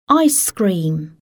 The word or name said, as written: ice cream